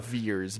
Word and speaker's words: Vers: He pronounces it Veers, but when you see it spelled, it's like Carol Danvers.